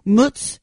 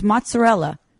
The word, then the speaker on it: mozzarella: It's not mutz. It's mozzarella.